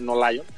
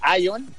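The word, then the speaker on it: Aion: la pronunciación es con A, Ion, no Lion, Ion de GAC, si no se vaya ahí a prestar a...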